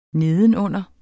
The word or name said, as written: nedenunder